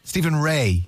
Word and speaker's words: Stephen Rea: How do you pronounce it? Stephen Ray, I think, isn't it? – Stephen Ray. – Yeah, the Northern Irish actor.